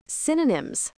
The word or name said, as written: synonyms